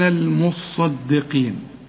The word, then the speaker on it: المصدقين: شوف، حنقول إن المصدقين، المصدقين والقاف، اوعى تطلعها بين القاف والكاف، والمصدقات برضو نفس النطق، والمصدي دي بس يجيب القاف مفتوحة، والمصدقات القاف بعدها ألف هي، وأقرضوا الله، ها صح ولا خطأ؟